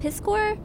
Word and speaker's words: Piskor: And then Ed Piskor.